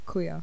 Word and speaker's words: Cooyah: Some of you might actually know there is a brand of kuya that was around, like, I don't know, is kuya still around years I think ago.